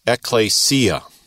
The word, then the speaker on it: ekklesia: And we'd spell it in English E-K-K-L-E-S-I-A. And you could put a line over that second E to show that's a long E, whereas the first one's a short. So you pronounce it ekklesia.